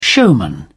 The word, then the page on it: showman: British English